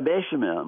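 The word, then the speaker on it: béchamel: Pardon my French here, bechamel. Am I saying that right? – Yeah, bechamel.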